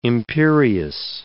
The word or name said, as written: imperious